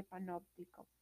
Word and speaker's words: panóptico: Esta palabra es una palabra esdrújula, la cual se acentúa en la antepenúltima sílaba, que viene siendo la no de panóptico.